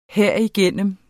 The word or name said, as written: herigennem